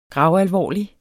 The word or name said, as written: gravalvorlig